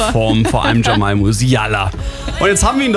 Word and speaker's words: Jamal Musiala: In ihrer Story bedankt sie sich beim FC Bayern-Spieler wie Luis Diaz, Harry Kane, Jamal Musiala und Alfonso Davis. Habe ich alles richtig ausgesprochen, oder? – Ja, korrekt.